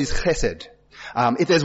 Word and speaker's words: chesed: It's got a bit of a ch attached to it, even though it's spelled with an H. Chesed.